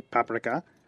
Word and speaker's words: paprika: Some people say paprika. – Well, in Hungarian, you'd call it paprika.